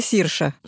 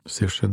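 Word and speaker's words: Saoirse: Так вот, ее имя пишется Сауирсе. – Ну да, да, да. – Говорится Сирша. – Сирша, да. Абсолютно верно.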